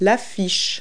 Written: poster

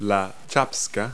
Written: schapska

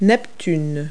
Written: neptune

